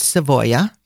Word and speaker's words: Savoia: Also, you've probably all heard of the Savoy dynasty, and Savoy is pronounced Savoia in Italian